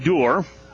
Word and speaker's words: Doerr: It's spelled D-O-E-R-R. Could go with door, but they pronounce it door.